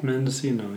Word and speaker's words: Mendocino: Made in Mendocino. Is that how you pronounce it? – Mendocino, yeah.